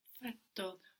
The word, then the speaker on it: fatto: Fatto. – And you can hear I'm pronouncing two T's here, fatto, F-A-T-T-O.